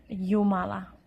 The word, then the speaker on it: Jumala: In Finnish, we pronounce it ye, ye. For example, in the word Jumala.